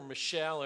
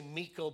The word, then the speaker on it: Michal: Then he concentrates on Michal or Michelle, however you want to pronounce that. I say Michal because that's the way it looks like in the Hebrew text.